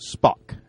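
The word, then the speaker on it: Spach: pronounced Spock, as in the guy from Star Trek.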